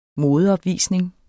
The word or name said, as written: modeopvisning